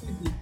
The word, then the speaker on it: Setti: Sí, Agustín Seti. – Aunque en realidad tengo conocidos que me dicen Chetti o Chetti porque es un apellido italiano.